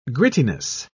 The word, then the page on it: grittiness: British English